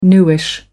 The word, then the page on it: newish: American English